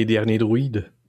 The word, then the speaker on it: Derniers Druides: Hatte aber den französischen Titel Les Derniers Druides, aber das kannst du besser aussprechen als ich. – Nee, Dernier Druide.